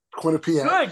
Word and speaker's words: Quinnipiac: I'm going to go Desi Jones of Quinnipiac. Is that how you say it? Quinnipiac.